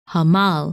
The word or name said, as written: Hamal